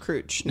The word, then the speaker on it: Crouch: I like that you have adopted the correct pronunciation of Crouch now. – Yes. Yeah, after last week, I had to. I'm going to be correcting myself and anybody else who says crutch from here on out.